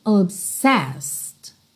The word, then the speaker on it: obsessed: People say I'm obsessed. Obsessed. Cuidado que o último E não é pronunciado, então você não diz obsessed, ok? Obsessed. Everything you say is lies.